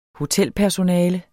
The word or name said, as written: hotelpersonale